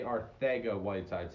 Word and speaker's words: Arcega-Whiteside: It's J.J. Arcega-Whiteside. Say it like Mike Tyson would. Not Arcega, Arcega.